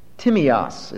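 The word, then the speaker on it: Timios (τίμιος): Timios is the way the word is pronounced. T-I-M-I-O-S in English letters.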